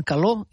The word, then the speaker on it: calor: Doncs a veure si algú la pot ajudar, perquè diem amor i diem calor i no fem i no pronunciem la R final.